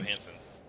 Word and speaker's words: Johansson: Scarlett Johansson? – Johansson.